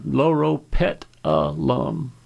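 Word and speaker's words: Loropetalum: L-O-R-O, Loro, P-E-T-A-L-U-M, Laura Petalum.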